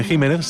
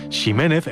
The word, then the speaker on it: Ximénez: Así, por ejemplo, la variedad de una uva conocida como Pedro Jiménez se pronuncia así, aunque ese Jiménez se siga escribiendo con X, si bien también se escucha mucho decir Ximénez e incluso Ximénez cuando se pierde ya la referencia de su pronunciación original.